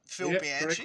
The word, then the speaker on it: Bianchi: Phil Bianchi, correct.